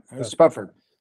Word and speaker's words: Spufford: The trick of these novels, the introduction in the tour book, Three Californias by Francis Spufford. I'm not sure how to say his name. – Yeah, Spufford.